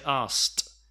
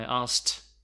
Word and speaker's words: asked: It's more important to say the t so it's better to say asked. Here I'm not even saying the k. A lot of natives do not pronounce the k. I asked, I asked him, asked.